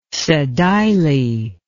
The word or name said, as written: sedile